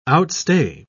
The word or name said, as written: outstay